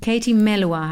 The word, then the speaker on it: Katie Melua: Und der zweite Nachtrag war, du hast total recht, Katie Melua spricht sich genauso aus. Ich habe immer Katie Melua gesagt, weil ich das verwechselt habe, glaube ich, mit irgendeinem alkoholischen Getränk. Mhm. Katie Melua heißt die. – Melua, ist richtig.